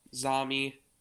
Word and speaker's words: Zami: Chaim, Zami, we learned afterwards was the correct pronunciation.